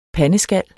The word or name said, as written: PANDESKALS